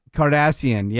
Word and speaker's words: Cardassian: Cardassian.